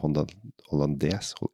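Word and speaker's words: hollandaise: Så den her kommer til å være en sånn der dans gjennom hollandesen, eller hollandes, jeg er litt ute på sånne franske ord. Ja, hollandes, ja. – Du uttaler resten på slutt, faktisk. – Så jeg kan ikke si hollandé? – Nei.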